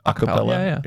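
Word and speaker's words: a cappella: Acapella. Is that how you say it? – Acapella. Yeah.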